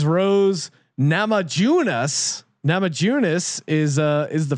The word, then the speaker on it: Namajunas: Well, first of all, tell me that you really think that that's how you pronounce the last name of Rose. – Just really go through that one again. Nama Junas. That, that was better. Yeah. Namahunas. Rose yeah. Namajunas.